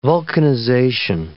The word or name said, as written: vulcanization